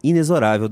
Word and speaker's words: inexorável: A capciosa letra X, em inexorável, deve ser pronunciada como Z e não como CS ou CZ.